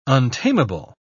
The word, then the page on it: untamable: British English